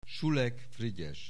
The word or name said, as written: Schulek, Frigyes